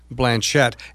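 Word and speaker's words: Blanchet: It's named after the first Catholic Archbishop of the Northwest, Archbishop Francis Norbert Blanchette. And by the way, his family pronounced it with the hard T, even though they were of French heritage.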